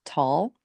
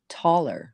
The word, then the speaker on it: Tolle: If you Google how to pronounce Eckhart Tolle, it'll say tall. – That's why I always think the E shouldn't be pronounced, but I don't know. – And then if it's the German way, which he's German, it rhymes with dollar. So it would be taller.